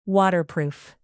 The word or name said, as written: waterproof